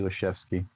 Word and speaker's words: Leschewski: He tended to do the W more as a V, like a German V, like a chef's V, like soft F. but Ushuski, Leshevsky, we're good.